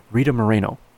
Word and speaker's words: Rita Moreno: Oh, sorry, I said her name wrong. Rita Moreno.